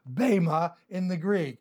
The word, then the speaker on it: Bema: Obviously, "for we shall all," here we have Paul including himself, "we shall all stand before the Bema," the Greek word B-E-M-A, the E is pronounced as a long A, Bema, the judgment seat of Christ.